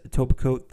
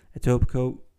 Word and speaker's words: Etobicoke: So they moved the brewery from Brampton to their current day location in Etobicoke. Now, one thing I wanted to mention is Etobicoke, that's how you say it, not however they said it in that Netflix documentary. Etobicoke, that is the proper pronunciation.